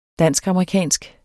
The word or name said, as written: dansk-amerikansk